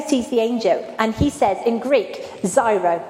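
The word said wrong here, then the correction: chaire: Have I said that right? Is it Zairo? Cairo. Thank you, my Greek specialist over there.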